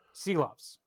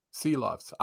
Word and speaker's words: Silovs: How do you say his last name? Seelovs. Seelovs.